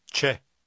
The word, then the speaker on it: c'è: Посмотрите на второе слово в номере 6. Оно произносится «Че».